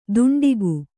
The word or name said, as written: ದುಂಡಿಗು